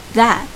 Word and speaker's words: that: 是我们今天要学的新单词，that that t h a t t h a t that that t h 和 this 是一样的，也是轻咬舌尖的发音，the，而后面呢是张大的一个口型。